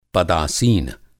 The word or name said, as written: पदासीन